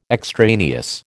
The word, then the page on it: extraneous: American English